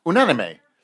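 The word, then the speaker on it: Unánime: Just corrected my pronunciation. I'm usually a bit of a bitch at correcting his pronunciation when he does French. Say that again, please. – Unanime. – I think that's correct.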